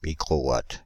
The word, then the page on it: microwatt: France (Île-de-France); /mi.kʁo.wat/